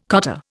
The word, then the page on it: cottar: Indian